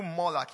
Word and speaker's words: malak: The word king in that scripture is derived from the Hebrew word pronounced Malak, but spelled Malak. M-A-L-A-K. But it's pronounced Malak.